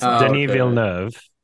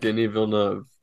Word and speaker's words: Denis Villeneuve: I know how to pronounce it correctly because I'm Canadian and I know some French, but it's Denis Villeneuve, and he's from Quebec. – Denis Villeneuve. – As in like Denis, and then Ville as in like baby cow, and then Neuve as in...